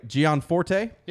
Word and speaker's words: Gianforte: Greg Gianforte.